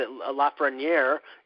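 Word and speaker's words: Lafrenière: In my personal situation, I will be upset if Montreal wins it, because everybody knows that the Lafreniere, you know, they want the French-Canadian player to go number one and go to the Habs. – You pronounced that right. Good for you.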